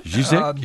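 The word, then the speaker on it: Žižek: Yes, and we're going to come to Mr. Zizek, is that how you say it? – Zizek, yeah.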